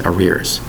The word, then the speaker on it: arrears: The word is arrears, spelled A-R-R-E-A-R-S. That's right. Three R's in the word arrears. Two syllables for the word arrears. A-R-R-E-A-R-S.